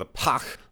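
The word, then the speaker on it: Pagh: On the Klingon ship, which is the Pach, spelled with a G-H, you really get that sound in there.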